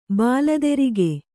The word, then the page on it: ಬಾಲದೆರಿಗೆ: bāladerige